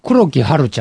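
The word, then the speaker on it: 黒木華: 例えば若手で言えば黒木春ちゃんとか。 – あ、花と書いて春って読むんだよね。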